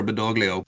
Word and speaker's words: Badoglio: Marshal Pietro Badoglio.